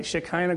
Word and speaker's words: Shekinah: Did I say that right? Shekinah glory? Okay, sure. Sounds good.